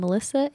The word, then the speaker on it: Alyssa: Aesthetics is spelled E-S-T-H-E-T-I-C-S. My first name, Alyssa, A-L-Y-S-S-A, Alyssa Aesthetics XO.